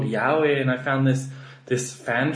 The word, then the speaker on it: yaoi: I started reading yaoi when I was... – Wait, you're pronouncing it wrong. It's yaoi.